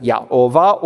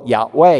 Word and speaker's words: Yahweh: And you can put the vowels where you could pronounce it Yahovah or Yahweh, either way.